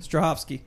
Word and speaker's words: Strahovski: Strahovski.